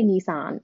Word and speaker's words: Nissan: It was a bright red Nissan or Nissan, where you're from, wherever you're from. – This um, is Nissan. – Nissan. Americans say Nissan. It's so oh, ah, okay. weird. It's Nissan, okay.